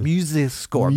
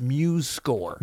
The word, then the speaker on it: MuseScore: So I'm doubly excited because today is the first time I learned how to say it. I was always like MuseScore. – MuseScore. – But it is, you've corrected me, it's MuseScore.